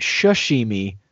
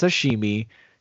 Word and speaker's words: sashimi: He pronounces it shashimi. – Does he? – I've only ever heard it pronounced sashimi.